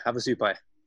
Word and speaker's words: Havasupai: Havasupai.